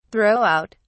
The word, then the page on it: throw out: British English